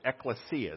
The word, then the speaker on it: ekklesia: It was ekklesia is how you'd pronounce it.